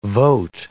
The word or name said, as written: vote